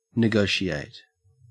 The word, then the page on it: negotiate: Australian English